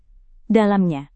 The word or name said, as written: dalamnya